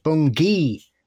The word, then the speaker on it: Bungay: Okay, so Bungay had me howling like the Black Shuck itself because it's pronounced Bungay with a hard G.